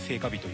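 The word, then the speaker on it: 盛夏日: 自宅演技で聖火日というものをやってます。盛る夏の日と書いて聖火日ですね。よろしくお願いします、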